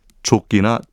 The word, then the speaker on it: 조끼: 혹시 기억하실지 모르겠지만 당시 생맥주집에서는 손잡이가 달린 커다란 잔을 조끼나 쪼끼라고 불렀습니다. 영어 단어인 저그를 일본식으로 발음한 게 우리나라에서도 그대로 쓰인 건데요.